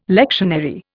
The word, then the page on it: lectionary: Indian